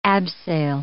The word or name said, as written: abseil